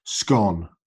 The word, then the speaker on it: scone: scone. – It's like it's gone, but with a skirt on the front.